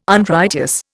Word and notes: Unrighteous: Indian

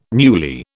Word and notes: newly: British English

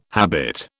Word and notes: habit: British English

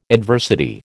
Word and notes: adversity: American English

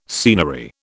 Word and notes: scenery: British English

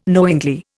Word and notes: knowingly: Indian

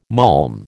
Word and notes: malm: American English

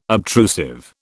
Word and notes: obtrusive: British English

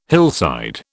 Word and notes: hillside: British English

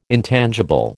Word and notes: intangible: American English

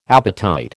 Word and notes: appetite: American English

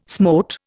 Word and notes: smote: Indian

